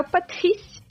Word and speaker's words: Patrice: é o Patrice do Assassin's Creed vocês vão ouvir falar dele mais adiante também. – Repete, por favor, esse nome. É só pronúncia francesa foi quase um vinho claro.